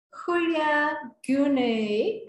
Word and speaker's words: Hulya Gunay: So today we have Hulya Gunay as a guest. Perfect pronunciation.